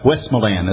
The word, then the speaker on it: Westmoreland: Westmoreland, as they pronounce it there.